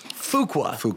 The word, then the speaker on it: Fuqua: Am I saying it properly? – I think Fuqua. Yeah. Fuqua.